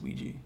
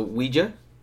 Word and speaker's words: Ouija: I think it's pronounced Ouija. – I know. I just was You're saying just it. Being a yes. Yeah. I'm just saying it funny because it's how it's spelled.